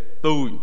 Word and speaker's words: Thuy: If you see my name, it's spelled Touch. Everybody actually call me Two, like the number two. But the real way to say it is Thuy.